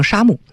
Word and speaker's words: 杉木: 咱们面对的木头和他们面对的木头，中国人从早期盖房子的时候用的就是相对松软的，在北方用松木，南方用沙木，就是或者是杉木，念成南方用杉木，或者也可以念成沙木。